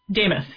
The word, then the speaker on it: Demuth: Is it DeMuth? How does she pronounce her name? Lisa DeMuth? How does she say it? – DeMuth. Yep.